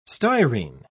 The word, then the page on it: styrene: British English